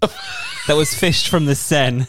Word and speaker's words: Seine: It's pronounced Seine.